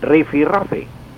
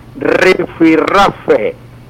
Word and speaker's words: rifirrafe: La palabra se escribe en una sola, rifirrafe, pero con doble R, o sea, con R, que llamamos antiguamente la R. Dos R's.